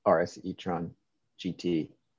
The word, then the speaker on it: RS e-tron GT: Don't let the AI do it on the commute, but I'd like to enjoy the RS e-tron GT.